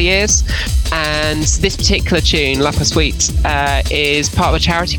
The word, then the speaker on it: La Poursuite: La Poursuite. Is that how you pronounce that? – Yeah.